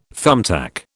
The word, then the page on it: thumbtack: British English